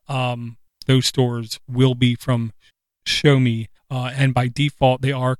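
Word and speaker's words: Xiaomi: And one of the things that they did was they teamed up with ShowMe, which is spelled nothing like the word sounds like, ShowMe, X-I-O-M-I-A or something,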